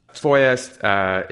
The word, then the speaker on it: FOIA: FOIA is the pronunciation of the acronym for the Freedom of Information Act,